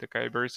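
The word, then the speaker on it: açaí: How did that start, and how did that entrepreneurial journey, how did you find out about Ekaya Beans, am I pronouncing that right? – Yeah. I think they call it acai berry, okay. No, we call it acai berry.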